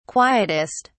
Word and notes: quietest: British English

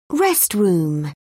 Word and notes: restroom: British English

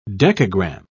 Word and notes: decagramme: British English

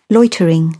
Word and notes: loitering: British English

